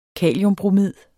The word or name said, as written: kaliumbromid